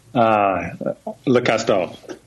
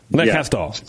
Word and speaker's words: Le Castor: You pronounced the S too hard. – Le Castor. How do you say it? – Le Castor. – Le Castor. – Short R. Yeah, you're right. Not Leur, not Le Castor. Castor. Okay. Yeah. You're very close, though.